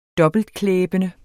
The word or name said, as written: dobbeltklæbende